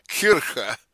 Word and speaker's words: Kirche: Kirke, same in German, Kirche. It's pronounced with a CH, a guttural CH, which is Hebrew. That's where Germanic preserves the Hebrew CH sound, Kirche, all right?